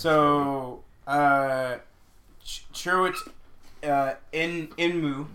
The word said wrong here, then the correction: Chirrut Imwe: Chirrut Imwe.